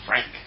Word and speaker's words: Phranc: No, she say a soft F. But a hard P. No, a hard C. A soft P. Frank. P-H-R-A-N-C.